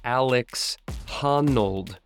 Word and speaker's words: Alex Honnold: Hey y'all, I'm Ryan Devlin, and welcome to the Struggle Climbing Show's Training Tip Tuesday, this week featuring a young up-and-comer by the name of Alec Honnold. Sorry, just was reading my notes wrong there. It is pronounced Alex Honnold. Honnold, Alex Honnold.